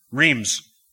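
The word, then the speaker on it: Rheims: But before we get to that, the Rhymes Douay Bible of 1582. Now, just in case I pronounced that wrong, how would you say that? Rhymes Douay? Reams. Did I get Douay right? Okay, Reams Douay.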